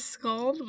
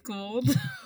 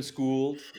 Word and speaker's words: Skuld: I do want to say something because everyone says Skuld, but it's actually pronounced Skuld. So what? No, okay, wait. – As in, I have been schooled in order to, like... – Sometimes we say that as a joke. It's named after the Norse goddess of the future, so it's Norse pronunciation.